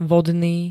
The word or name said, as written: vodný